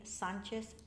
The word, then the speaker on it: Sanchez: Velia, V-E-L-I-A, Sanchez, S-A-N-C-H-E-Z, hyphen, R-U-I-Z.